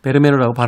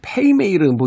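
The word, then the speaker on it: Vermeer: 이게 베르메르라고 발음하는 게 맞습니까? 했더니 본토 발음에 가깝게 하려면 페이메이르 뭐 이렇게 발음을 해야 된대요.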